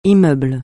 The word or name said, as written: immeuble